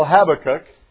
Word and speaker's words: Habakkuk: The real pronunciation in the Hebrew that we call Habakkuk is Habakkuk. It sounds like Habakkuk.